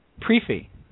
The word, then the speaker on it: prix fixe: Pre-fee. – Well, pre-fee, like beefy? – Pre-fee. Fee, because PRIX is pre, FIXE should be fee as well, because the X is pronounced as an E.